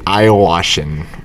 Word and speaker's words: ayahuasca: The ayahuasca.